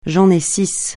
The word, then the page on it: six: francais